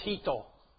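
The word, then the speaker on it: hito (人): And that's pronounced hito. Hito.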